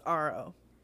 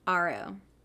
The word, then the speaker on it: Aro: And we meet Arrow. – Yeah. – Is that how you pronounce his name? – I think it's Aro. – Aro. Okay.